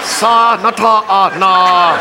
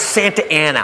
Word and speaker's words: Santa Ana: Sa-na-ta-na. How? It's Santa Ana. What says Santa Ana? Look, I know I didn't finish school, but that frickin' says Sa-na-ta-na. They pronounce it Santa Ana.